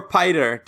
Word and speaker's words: Piter: Or Piter, depending on how you read it. It's spelled P-I-T-E-R.